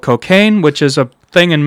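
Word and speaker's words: Cockaigne: Yeah, it is pronounced Cocaine, but I'm just to emphasize, there's a G.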